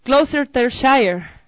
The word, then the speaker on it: Gloucestershire: Gloucestershire.